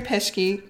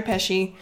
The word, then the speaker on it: Pesci: It's Joe Pesci. – Pesci. – I always pronounce his name wrong. Joe Pesci.